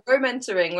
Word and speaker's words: Womentoring: Yeah, Womentoring, like mentoring for women, by women, for women.